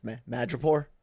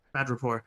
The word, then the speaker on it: Madripoor: He gets Sam Wilson to play dress up because they're going to Madripoor, and which in the comics, what, how's it pronounced? – I think it's Madripoor. – Madripoor.